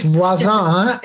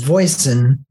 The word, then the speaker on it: Voisin: It's Voisin, and it means neighbor. – And it means neighbor. But how you now pronounce it in this country is Voisin. – Voisin. – Voisin. Yes.